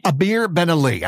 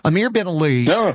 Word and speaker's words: Amir Ben Ali: So Chief Inspector Burns started accumulating suspects, and then Amir Lee Mani, how do you say his name? I'm sorry. – Amir Ben Ali. – I'm sorry. Amir Ben Ali becomes a witness.